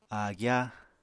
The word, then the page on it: áagyá: apatani